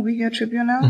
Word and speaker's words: Uyghur Tribunal: Erstens, habe ich das richtig ausgesprochen, Uyghur Tribunal? Mhm.